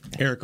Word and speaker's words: Arik: and I don't know if I'm pronouncing her name correct, is Arik is just with an A. – Arik Armstead. Arik yeah. Armstead. Yeah, yeah. Okay. Yeah. All right. I wasn't sure with the E and A.